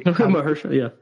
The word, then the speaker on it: Mahershala: Does Mahershala, maybe I said that wrong. Sorry. – Mahershala.